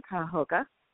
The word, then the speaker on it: Cuyahoga: We sit in a county that most people cannot pronounce. It's an Indian name, Cuyahoga.